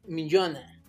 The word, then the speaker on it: Minyona: que se llama la torre de Min Minyona. No sé cómo se pronuncia porque... – ¿La torre de qué? – Minjona M-I-N-Y-O-N-A.